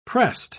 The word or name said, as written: pressed